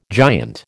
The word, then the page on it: giant: American English